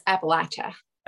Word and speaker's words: Appalachia: A lot of people mispronounce it. That's how it's taught in school, but that is actually the incorrect pronunciation. – Okay. – Is Appalachia. You always put the short A.